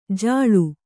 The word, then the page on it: ಜಾಳ: Kannada